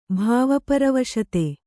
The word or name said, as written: ಭಾವಪರವಶತೆ